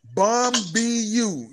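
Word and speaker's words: Bumbu: No, I'm laughing because I've never heard of bamboo. – No, it's you pronounce it just like it's spelled. Bum B U.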